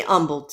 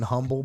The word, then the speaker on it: Humble: you put a silent H in humble, but it's whatever well, because humble and humble.